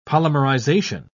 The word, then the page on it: polymerization: British English